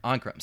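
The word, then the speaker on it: Ancrum: Ankermore. – Okay, call it something else for now if you're going to keep referencing it. – Ankrum. So it's A-N-C-R-U-M. Ankrum.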